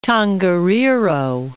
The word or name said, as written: Tongariro